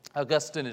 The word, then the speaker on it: Augustine: You may hear him called Augustine. Augustine is generally considered the most correct way to pronounce his name.